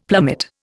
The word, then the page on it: plummet: Indian